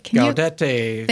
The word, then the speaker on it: Gaudete: So the third Sunday of Advent, I don't know how to properly pronounce that Sunday. – Gaudete.